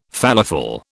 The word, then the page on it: falafel: British English